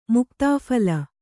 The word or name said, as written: ಮುಕ್ತಾಫಲ